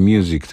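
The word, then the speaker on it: Music (µ-Ziq): Nazywa się on Mike Paradinas, a występuje pod pseudonimem Music. No w zasadzie Music to się czyta, pisze się przez literkę Mi i tak dalej, i tak dalej.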